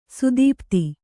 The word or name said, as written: ಸ್ಥಪತಿ